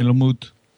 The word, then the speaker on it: Helmut: Am I saying that right? It is Helmut, right? – Helmut. Helmut. Helmut is fine for English speakers like you and I.